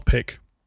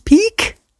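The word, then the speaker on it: Pic: That it's actually pronounced Charles Peak? – That's it, is it? – That's pretty much it, yeah.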